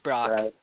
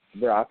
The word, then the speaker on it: Brock: For me, I expect Pedro Strop to slot into the closing role, but I would not be surprised if Brad Brock, is that how it's pronounced, or Brack? – Yeah, it's Brock. – Brock, okay.